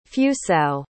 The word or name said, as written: fusao